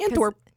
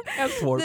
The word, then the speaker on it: Antwerp: So today we are talking about the Antwerp Diamond Heist. I believe I pronounced that right. – Antwerp. – Of the video, Antwerp.